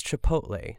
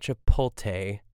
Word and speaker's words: chipotle: It is, I understand the struggle with it, but it's chipotle. It's not chipotle, which I think is the most common mistake.